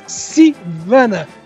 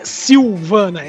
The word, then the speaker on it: Sivana: Mas depois disso, né, ele vai aprender sobre as responsabilidades dele e então enfrentar o vilão, o Dr. Thaddeus Sivana. Não é Silvana, é Sivana. Que é interpretado. – Tinha que chamar de Dr. Sivana, é sempre melhor. – Não, é Sivana, que pronunciava errado antigamente.